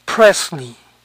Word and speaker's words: Presley: Este apellido se pronuncia Presley, Presley. Entonces, EY final suena I. Presley, Harley, Parsley.